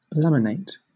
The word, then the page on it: laminate: British English